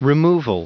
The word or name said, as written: removal